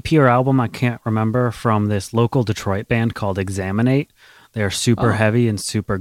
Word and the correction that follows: Exanimate: Or Exanimate is how you say their name. It's Exanimate. They're super cool. – Exanimate.